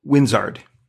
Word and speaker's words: Winzard: One of the things I want to also talk about is your work with Winzard. See if I'm pronouncing that one right. – It's like, you are.